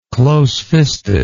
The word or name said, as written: closefistedness